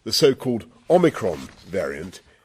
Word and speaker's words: Omicron: It's pronounced Omicron. Omicron.